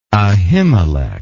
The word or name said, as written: Ahimelech